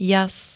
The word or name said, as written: jas